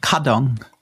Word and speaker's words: Kardon: Du hast das so schön anders ausgesprochen als ich, mit dem K vorneweg. – Der Kardon.